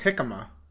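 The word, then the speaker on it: jícama: So what I did, um, I had I always say this wrong. Is it Jamaica? I don't know. – I thought it was jicama. – Oh, jicama? Oh, jamaica yeah, is the flower. Jicama.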